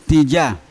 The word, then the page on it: tija: Yawa